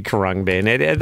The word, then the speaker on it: Krongbin: It sounds like it could be Krongbin. That's how it's spelled, too.